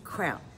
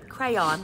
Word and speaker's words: crayon: Crayon. Two syllables. – Okay, this is an orange crayon. Crayon is how you say it.